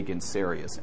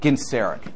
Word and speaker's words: Genseric: I always want to say Gensarius, and that's close to his name, but it's not the correct pronunciation. Genseric. Thank you, Genseric.